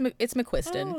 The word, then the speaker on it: McQuiston: It's McQuiston.